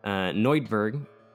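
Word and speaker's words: Neuburg: So, Neudberg, that's the pronunciation. I'm doubling down on my pronunciation.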